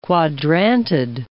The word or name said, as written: Quadrantid